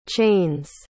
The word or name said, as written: chains